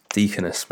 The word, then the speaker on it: Deaconess: Deaconess Medical Center.